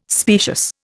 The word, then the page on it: specious: Indian